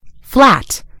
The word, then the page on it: flat: British English